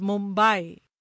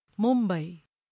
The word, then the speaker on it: Mumbai: International television channels refer to the city as Mumbai, when it should be pronounced in its authentic Marathi way, Mumbai, like Hawaii.